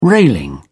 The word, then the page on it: railing: British English